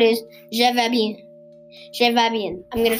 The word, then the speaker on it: bien: It's spelled a little bit like bean, not really. Bien, B I E N.